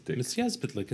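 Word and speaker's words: Mxyzptlk: Mizyezplitlik. – It's just like it's spelled. – Mizyezplitlik. – Mizyezplitlik. There's no sh sound.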